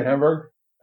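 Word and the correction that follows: Heimburg: How do you say his name again? – Heimberg.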